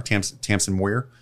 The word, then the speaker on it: Tamsin Muir: It's written by, I think she's a New Zealander, so I'm sorry if I mispronounce the name, but it's Tamsin Muir, I think. M-U-I-R. Tamsin Muir.